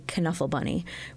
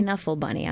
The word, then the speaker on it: Knuffle Bunny: And then the following day, we will have a performance of The Knuffle Bunny, which is a much beloved children's book.